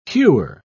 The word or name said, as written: hewer